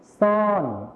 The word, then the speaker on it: ช้อน: ครับเรามาต่อกันที่แผ่นภาพที่ 2กันเลยนะครับนักเรียนภาพนี้ก็คืออุปกรณ์ที่เราใช้ในการตักอาหารรับประทานนั่นเองครับนักเรียนตอบมาว่าช้อนนั่นเองครับใช่แล้วครับภาพนี้ก็คือภาพของช้อนนั่นเองนะครับนักเรียนลองอ่านพร้อมกันสิครับช้อนอีกครั้งนึงครับช้อนแล้วคำนี้ล่ะครับซ่อนออกเสียงพร้อมกันนะครับซ่อนใช่แล้วครับในภาษาไทยมาตรฐานนะครับเราจะใช้ชอช้างนั่นเองส่วนในภาษาถิ่นอีสานนะครับเราจะใช้ซอโซ่นั่นเองครับ